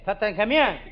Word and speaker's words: Tutankhamun: How do you say it? How it? Tutankhamun.